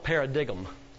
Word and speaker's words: paradigm: It's spelled paradigm, but it's pronounced paradigm.